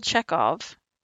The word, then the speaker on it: Chekhov: 27, an American actor known for portraying Pavel Chekhov. – Mm-hmm. – Is that how you say it? – Mm-hmm.